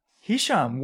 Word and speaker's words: Hisham: How do you pronounce it? – Hisham. Wow. Shocking. I'm shocked on that one. – It's Hisham. You're 100% sure it's Hisham.